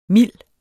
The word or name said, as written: mild